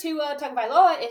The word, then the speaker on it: Tua Tagovailoa: And if you'll remember, Tua Tagovailoa is also still questionable for this weekend.